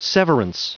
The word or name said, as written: severance